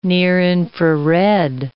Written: near-infrared

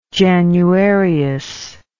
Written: januarius

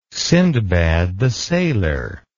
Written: Sindbad the Sailor

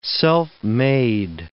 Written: self-made

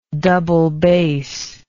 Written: doublebass

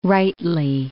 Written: rightly

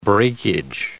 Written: breakage

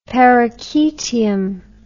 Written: perichaetium

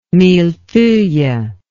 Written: millefeuille

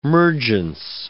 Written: mergence

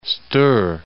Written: Stour

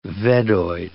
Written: Veddoid

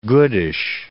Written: goodish

